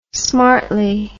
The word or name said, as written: smartly